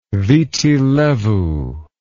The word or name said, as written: viti levu